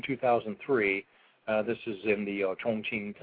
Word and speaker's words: Chongqing: He's an assembly line worker in a factory in Chongqing, China. That's how you actually pronounce it.